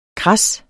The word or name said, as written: kras